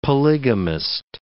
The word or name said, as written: polygamist